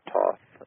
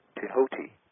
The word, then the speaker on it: Thoth: And it dawned on me while I was there, the Hermes Trismegistus had, of course, an Egyptian name spelled T-H-O-T-H. Many people pronounce this Thoth or Toth. But actually, the proper pronunciation is Tehoti.